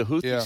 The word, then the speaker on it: Houthi: I do a hard TH. Houthi. – Houthi. – Like a D, but yeah.